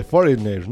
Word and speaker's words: Foreigner: De estos grupos extranjeros o foreigner. ¿Cómo se pronuncia correctamente? Foreigner, ¿no? – Foreigner.